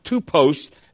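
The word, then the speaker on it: tupos (τύπος): That word pattern there is really a simple Greek word that that's pronounced tupos.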